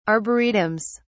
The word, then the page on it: arboretums: British English